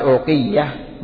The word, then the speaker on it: أوقية: أواق، أواق جمع أوقية، أواق جمع أوقية، يقال أوقية بضم الهمز أوقية وكسر القاف أوقية وتشديد الياء أوقية، آخرها تاء مربوطة، وكثير من الناس ينطقها بدون همز يقول أوقية، وبالأخص العامية.